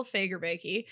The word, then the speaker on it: Fagerbakke: Is that how you say it? – Yes. Bill Fagerbakke played Sparky,